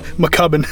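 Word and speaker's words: Mackubin: That's how everybody mispronounces my name, you know, my entire life. It's been McCuban. – Oh, really? – Oh, what is it for real? – McCubbin.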